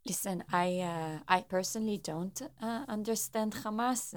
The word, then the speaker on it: Hamas: Hamas. Rightly pronounced Hamas, but not to be confused with Hummus, is an acronym of Harakat al-Muqawama al-Islamiyya, Islamic Resistance Movement.